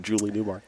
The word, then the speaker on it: Newmar: Can't believe I butchered Julie Newmar.